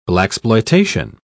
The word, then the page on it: blaxploitation: British English